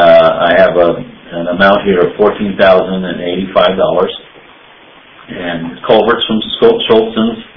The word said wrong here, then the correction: Shelton's: How do you say that? – Shelton.